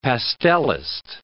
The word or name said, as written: pastellist